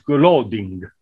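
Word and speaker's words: Glading: G-L-A-D, like the word glad, I-N-G. It's actually an old Norwegian name that means people that are happy. – Oh, wonderful. – It should be pronounced Gloding.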